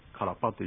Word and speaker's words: カラパ: ですから本来はクアラパルスイで、これがカラパルスイというふうになりますので、他の地域ではクアラパというふうに言う場合もありますが、猿川流域ではカラパと言いますので、この辺は気をつけて勉強していただきたいと思います。